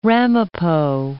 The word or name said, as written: Ramapo Mountains